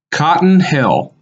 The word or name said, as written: Cotton Hill